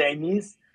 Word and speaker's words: Enis: Ich heiße Enis, E-N-I-S geschrieben, ausgesprochen grammatikalisch mit Enis, ist korrekt, aber ich komme aus Nordafrika, aus Tunesien und da heißt der Name Enis.